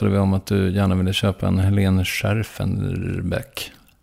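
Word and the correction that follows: Schjerfbeck: Jag kan inte uttala det. – Scherfbeck. Ja. Helene Scherfbeck.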